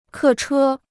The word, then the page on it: 客车: kè chē